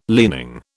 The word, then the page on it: leaning: British English